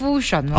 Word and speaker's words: fusion: 人哋话头先你讲个咩 function 唔系 function 系 fusion 哦，fusion 啦，fusion 啦，我啲发音都系乱嚟嘅啫吓，唔好介意啊。